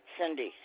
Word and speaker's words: Cindy: Cindy, C-I-N-D-Y. Okay. – And your last name, is it K-L-I-N-E? – No, ma'am, K-L-E-I-N.